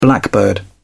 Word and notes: blackbird: British English

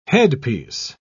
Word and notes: headpiece: British English

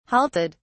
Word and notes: halted: British English

